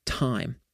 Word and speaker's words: Tyme: Now, the next app that I use is called Time, but it's spelled T-Y-M-E.